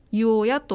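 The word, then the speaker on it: 井親: で、この大市の庄のご家村が、湯おやというものになりまして、これも井戸の井に親と書いて湯おやと呼びます、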